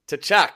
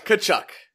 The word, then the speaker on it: Tkachuk: This is Brady Tkachuk. – Nope. – Tkachuk. – Tkachuk. – Tkachuk. – The T almost doesn't exist.